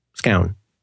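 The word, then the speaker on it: Scown: Oh, and I should mention in passing here, Greg, how do you say his last name? Scone? Scone. Because that sounds like a breakfast Scone, treat.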